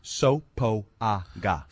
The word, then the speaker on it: Sopoaga: Well, I've always called him Sopago. I don't know why, just in my head. – Yeah. – And now it hurts me because that's not how you say it.